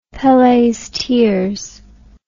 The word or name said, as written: Pele's tears